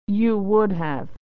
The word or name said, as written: you would have